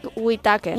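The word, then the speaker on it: Whitacre: amb obres com 5 cançons hebrees d'amor d'Eric Waikre. No, tu com ho pronunciaries? – A veure, Eric Whittaker. – Whittaker, no? – Està, vale. – No ho sé, té tota sa pinta.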